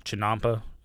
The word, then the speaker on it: chinampa: It's a chinampa. Chinampa is how you say it.